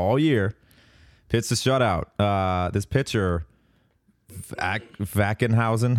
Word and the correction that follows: Ackenhausen: how do you say it? Sure. Dude played incredible. Nate Ackenhausen, six innings, four hits, no runs, seven Ks.